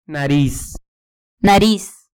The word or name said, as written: nariz